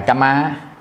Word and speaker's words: kamma: Tại vì khi mình làm xong thì mới có cái quả báo của nó, tức là cái hành nghiệp là cái kamma, tức là mình đọc là kamma,